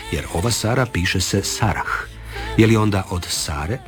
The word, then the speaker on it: Sarah: U nominativu i hrvatsko ime Sara i strano ime koje se piše Sarah izgovaraju se jednako.